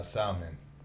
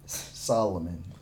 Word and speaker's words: Solomon: You got beat up by a salmon. – Solomon.